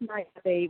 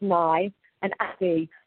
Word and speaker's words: Niabe: I told you to say it's Niabe, which is how I pronounce it. This is because I've spent so many, many years trying to explain to people how to say it. So I'm like, it's Nye and Abby.